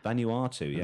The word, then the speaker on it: Vanuatu: Vanuatu, yeah.